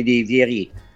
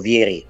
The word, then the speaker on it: Vieri: Ci sono dei giocatori che per esempio non giocano, per esempio nel ruolo di Vieri, o Vieri, sì, come vi piace più pronunciarlo. Ci